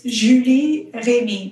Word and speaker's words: Julie Remy: Okay, it's Julie Remy, but I barely ever hear it properly.